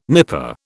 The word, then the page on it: nipa: British English